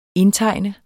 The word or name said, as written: indtegne